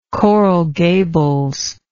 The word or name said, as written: Coral Gables